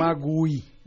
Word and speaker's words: Magui: Claro que mi nombre se escribe diferente, es M-A-G-U-I, para que me encuentren. – Magui. – Magui, blog, B-L-O-C-K.